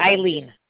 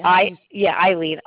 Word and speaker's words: Eileen: Eileen. – Eileen. Okay. – Eileen. I-L-E-E-N. – E-E-N. Okay. And your last name or initial? – Savins. S-A-V-I-N-S. – S-A-V-I-N-S? – Yes.